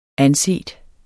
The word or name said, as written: anset